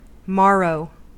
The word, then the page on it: morrow: American English